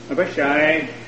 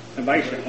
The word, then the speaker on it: Abishai: Following shortly after Bishop Chase in 1831, come Abishai, I'm getting that word pronounced wrong, my wife told me that too, Sanders. – Abishai. – Okay, thank you.